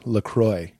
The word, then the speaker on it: LaCroix: It rhymes with boy, LaCroix.